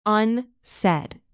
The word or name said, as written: unsaid